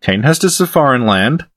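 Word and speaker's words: Cainhurst: Now I'm saying Kanehurst, look what you've done to me. – Yes, finally! Because that's the correct way to pronounce it. Kanehurst.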